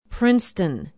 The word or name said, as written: princeton